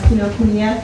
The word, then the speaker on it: Kinokuniya: This is our fifth panel member is John Fuller, and he is the manager of Kinokuniya. Am I pronouncing that right? – That's correct. Yes.